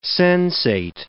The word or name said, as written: sensate